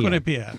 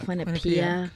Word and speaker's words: Quinnipiac: You pronounce it Quinnipiac? I've always pronounced it Quinnipiac. – Quinnipiac. – Quinnipiac. Okay.